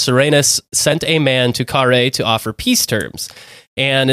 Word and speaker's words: Carrhae: It's C-A-R-R-H-A-E, so we're going with Carrhae.